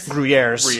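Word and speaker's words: Gruyères: The region actually has an S on the end. The cheese doesn't. But in Switzerland, it's Gruyere's. I mean, silent S, but.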